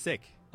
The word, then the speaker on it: Sikh: Like, So people say Sikh, so you're not, I mean, you're not mis-, that's what people say. It's actually pronounced Sikh. – Okay, Sikh. – Like the similar to the word, I'm not feeling well. – Okay. – Or as I like to, yo, that car is sick.